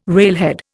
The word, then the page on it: railhead: Indian